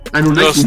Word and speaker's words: Anunnakis: Nos, este, nos dice que este planeta tenía a estos seres este Ananakis, Ananakis como se pronuncian, la verdad no, no, recuerdo muy bien. – Anunnakis, ¿no? Ajá.